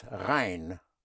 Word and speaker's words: rein: The word for clean is rein. Spelled R-E-I-N. Yes. Like a clean Rhine. The Rhine River is not very clean. Yes. But, yes. Of course, the Rhine River is spelled with R-H-E-I-N. Yes. But Rhine, R-E-I-N, means clean. Es ist rein. Another word for clean is sauber. S-A-U-B-E-R, sauber.